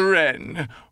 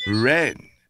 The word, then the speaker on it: Wren: K-N-I-G-H-T. So does know. K-N-O-W. Wren begins with silent W. W-R-E-N. So does write. W-R-I-T-E.